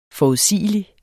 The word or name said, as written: forudsigelig